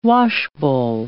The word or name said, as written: washbowl